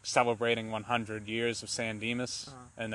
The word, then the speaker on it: San Dimas: I know that the town, I don't know how to say it, but San Dimas? – San Dimas.